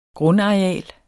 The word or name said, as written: grundareal